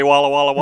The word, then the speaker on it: Walla Walla: I just wanted to hear somebody say Walla Walla, Washington.